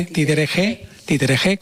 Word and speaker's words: Títere G: Eva Serna, que bueno, que son, digamos, los dos alma mater de este festival, Títere G, es un nombre que le han querido dar precisamente, Títere G, con ese acento en la E.